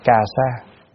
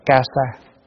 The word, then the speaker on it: cà sa: Kasa, kasaya mà người Việt Nam thường đọc là cà sa. Nhưng mà chữ đó mình đọc là ca sa cũng được, cà sa cũng được.